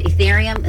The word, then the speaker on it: Ethereum: Ethereum.